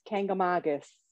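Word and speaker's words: Kancamagus: I don't know if I know, but I think it's Kankamankis. Kankamankis? – All right. – I'll have to look it up. – No, that sounds right. I've probably been pronouncing it wrong for 30 years.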